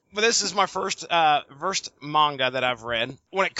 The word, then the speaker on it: manga: How is it pronounced, Coop? Manga or manga? – It's definitely manga.